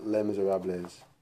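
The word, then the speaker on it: Les Misérables: It's L-E-S. It's The Miserable, basically, in French. – Okay. – Yeah. – Do you pronounce it Les Miserables? – Yeah, it's pronounced Les Miserables.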